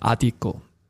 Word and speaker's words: Adico: Und er hat gesagt, ist die, die Betonung auf dem A oder auf dem I? Und er hat gesagt, na, es heißt Adico.